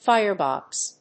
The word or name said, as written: firebox